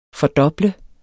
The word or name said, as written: FORDOBL